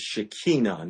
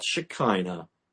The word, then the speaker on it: Shekinah: The word for that, one of the words for that in Hebrew is Shekinah, not Shekinah, as we say in English. They pronounce it in Hebrew,